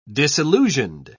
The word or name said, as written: disillusioned